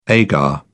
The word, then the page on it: agar: British English